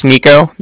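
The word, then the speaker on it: Niko: Okay, so yeah, like I said, my name's Nico. That's N-I-K-O. – Okay. – Calling from the Bank of Cardiff, and that's spelled C-A-R-D-I-F-F, as in Frank.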